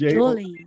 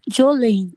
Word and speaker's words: Joelye: It's Jolene. J-O-E-L-Y-E. – And how do you pronounce that? – Jolene. Jolene.